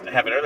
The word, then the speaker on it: Heavener: I believe it's pronounced Heavener. That's how it's spelled. – Heavener, yeah.